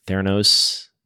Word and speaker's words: Theranos: like Theranos. Did I pronounce that correctly? – Yes.